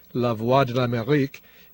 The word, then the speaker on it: Lavwadlamerik: In any event, we learned from looking at the VOA Creole website that in that language, the name of the station, although it sounds almost like real French, La Voix de l'Amérique, is spelled as one word, L-A-V-W-A-D-L-A-M-E-R-I-K. Creole forces French to be more phonetic.